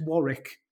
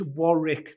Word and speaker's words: Warwick: And also Alex Warwick. So beautiful American pronunciation. It's Warwick, not Warwick.